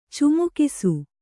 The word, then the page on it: ಚಮಕಿಸು: Kannada